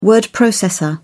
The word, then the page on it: word processor: British English